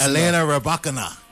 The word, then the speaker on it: Elena Rybakina: Felina Rybakina. I don't know how to pronounce that name. – Yeah. That's good. – That's close. – Elena Rybakina.